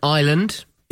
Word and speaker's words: island: Five words with hidden S words, silent S words. Isle. – Yes. – Island. – Yeah. – Debris. – Yeah. – Apropos. – Oh, nice. – And one I think you really like, bourgeois.